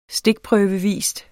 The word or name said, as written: stikprøvevis